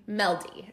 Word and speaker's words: Mjelde: Meldy. – Okay. Just ignore the weird J.